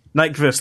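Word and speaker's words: Nyqvist: Michael Nyquist. – Nyquist, there we go.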